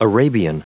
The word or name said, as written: arabian